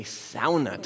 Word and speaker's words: sauna: Not a sauna tent. A sauna tent. That's how they say it in Finnish.